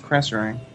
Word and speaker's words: Krasarang: Yeah, Karasang Wilds. – Oh, it's Krasarang. – Yes, I know, but when we first started talking about Miss Pandaria, we all mispronounced it, and...